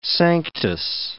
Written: Sanctus

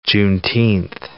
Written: Juneteenth

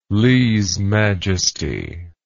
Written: leze majesty

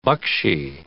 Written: buckshee

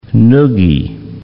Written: noogie

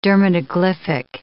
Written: dermatoglyphic

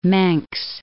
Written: Mengs

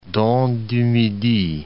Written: Dent du Midi

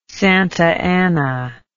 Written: Santa Ana

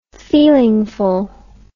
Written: feelingful

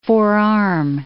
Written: forearm

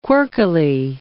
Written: quirkily

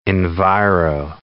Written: enviro

